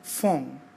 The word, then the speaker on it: phone: Cuando te encuentres con la formación PH, has de recordar que se pronuncia como si fuese una F, así de fácil y sencillo. Ejemplo, phone, se lee phone, que es teléfono, phone.